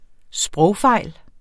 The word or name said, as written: sprogfejl